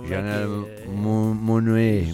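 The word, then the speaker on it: Janelle Monáe: Janelle Monet.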